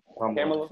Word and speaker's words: Kamala: I know I always say her name wrong. Kamala Harris. – Kamala. Mm-hmm.